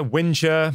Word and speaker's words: whinger: The British word is spelled W-H-I-N-G-E-R. How do you pronounce that? Winger or whiner or what do you say? – Yeah, whiner, whinger. – Yeah, ginger, whinger, they call them.